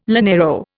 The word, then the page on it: llanero: Indian